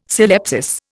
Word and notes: syllepsis: Indian

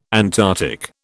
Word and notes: Antarctic: British English

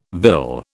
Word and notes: vill: British English

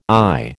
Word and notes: ay: American English

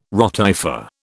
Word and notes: rotifer: British English

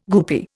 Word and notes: goopy: Indian

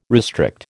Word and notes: restrict: American English

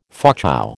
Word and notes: Fauchou: American English

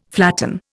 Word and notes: flatten: Indian

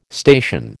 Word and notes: station: American English

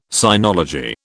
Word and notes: sinology: British English

